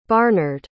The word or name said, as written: barnard